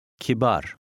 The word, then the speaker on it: kibar: The Turkish letter A is mostly pronounced as a short A, but some words contain a longer A, as in number 10. Listen and repeat. – Kibar.